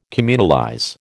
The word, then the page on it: communalize: American English